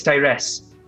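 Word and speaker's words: Taeress: Oh, my name is Tyrese.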